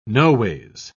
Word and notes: noways: British English

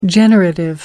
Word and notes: generative: American English

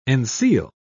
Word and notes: enseal: British English

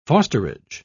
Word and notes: fosterage: British English

